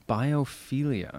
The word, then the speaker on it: biophilia: Biophilia.